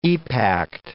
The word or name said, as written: epact